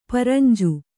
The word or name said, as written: ಪರಂಜ